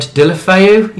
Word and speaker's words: Deulofeu: Deulofeu.